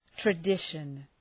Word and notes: tradition: trə’dıʃən

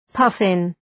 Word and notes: puffin: {'pʌfın}

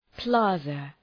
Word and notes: plaza: ‘plɑ:zə, ‘plæzə

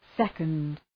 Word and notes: second: ‘sekənd

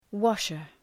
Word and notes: washer: ‘wɒʃər

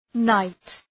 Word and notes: knight: naıt

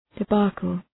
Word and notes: debacle: deı’bɑ:kəl

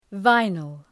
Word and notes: vinyl: ‘vaınıl

